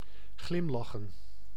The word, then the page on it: smile: /smiːlø/; [ˈsmiːˀlə]